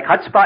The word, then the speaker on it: chutzpah: How do you pronounce that word? Huh? Like chutzpah? H U? Chutzpah.